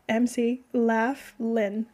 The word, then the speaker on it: McLaughlin: Like Mac, M-C, Laughlin. – McLaughlin.